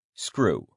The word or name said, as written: screw